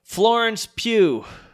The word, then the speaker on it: Florence Pugh: All right, best supporting actress, Kathy Bates for Richard Jewell, Laura Dern for Marriage Story, Scarlett Johansson for Jojo Rabbit, Florence Pugh, Little Women, did I say that right? – Yeah.